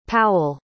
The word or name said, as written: powell